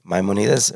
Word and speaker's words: Maimonides: So there's a quote from Maimonides. Did I say that right? – Maimonides, we said sounds like Batida from like Terra Luna.